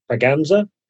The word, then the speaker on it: Braganza: And about 1662, England got a new queen, Queen Catherine of, and I cannot pronounce the name of Spain is what I'll say it, but it's Braganza. Braganza. There we go.